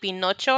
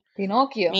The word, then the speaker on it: Pinocchio: Pinocchio, because she was calling it Pinocho or something like that in Spanish. I'm like, what are you talking about? And I was like, Mom, look, she's not saying it right.